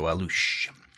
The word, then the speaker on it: Alux: and it's called the Alush, A-L-U-X. The X is pronounced with like an S-H, so Alush.